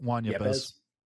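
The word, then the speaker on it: Juan Yepez: You got it, Juan Yepes.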